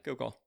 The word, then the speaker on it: Gilgal: Is that pronounced correctly? Gilgal? – Yeah, Gilgal. – All right, cool. Gilgal.